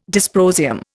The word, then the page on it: dysprosium: Indian